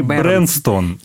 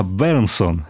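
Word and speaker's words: Berndtson: Не Бернстон, не Бернстан, а Бернсон.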